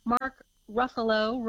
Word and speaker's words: Ruffalo: Mark Ruffalo rhymes with Buffalo, is always good as the Hulk.